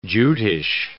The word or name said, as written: Jutish